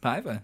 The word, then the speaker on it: Paiva: Paiva. That's a Portuguese last name.